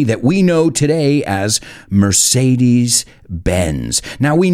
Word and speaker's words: Mercedes: And when I say the word Mercedes, or bueno, lo pronuncio a la española, Mercedes, what do you think of?